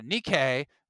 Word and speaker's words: Nikkei: And this week, literally this week, the newest report came from Nikki, how do you say that? – Nikkei, thank you. Nikkei, I like that.